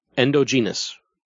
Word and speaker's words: endogenous: That is sequences of androgenous? – Androgenous? It's endo. Endogenous. Endo, yeah. – Oh, okay. I'm not seeing the words. – E-N-D-O-G-E-N-O-U-S.